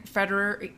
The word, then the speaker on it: Federer: Like, Federer.